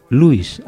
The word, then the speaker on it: Louis: Y bueno, hablando de la figura de Louis Armstrong, hay mucha gente que pronuncia el nombre de este señor como Louis Armstrong, pronunciando la S. Se escribe con S, algunas personas la pronuncian, otras no. Quiero decir, bueno, aquí en España todo el mundo dice Louis Armstrong. En Estados Unidos algunos dicen Louis, otros dicen Louis. Hay una polémica sobre si esa S se debe o no se debe pronunciar debido a la ambigüedad de la ortografía inglesa.